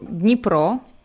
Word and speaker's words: Dnipro: Currently, the name is shortened to Dnipro because of our political situation in Ukraine right now.